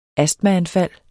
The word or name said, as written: astmaanfald